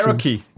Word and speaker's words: Cherokee: Wie spricht man das? Cherokee. Jeep Cherokee.